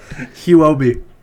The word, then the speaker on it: Huobi: Huobi.